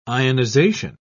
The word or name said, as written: ionization